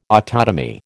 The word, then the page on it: autotomy: American English